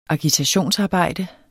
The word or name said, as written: agitationsarbejde